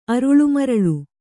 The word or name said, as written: ಅರುಳುಮರಳು